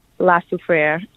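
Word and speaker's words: La Soufrière: La Soufriere.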